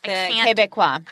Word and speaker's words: Québécois: because you do have the Bloc Québécois. Oh, God, I just butchered that. The Québécois. I can't do French. – Québécois. – Ah, there we go.